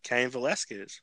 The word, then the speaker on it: Cain Velasquez: Cain Velasquez. – Yes, you said his name right. he has trouble with that name.